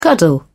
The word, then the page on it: cuddle: British English